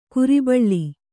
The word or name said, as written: ಕುರಿಬಳ್ಳಿ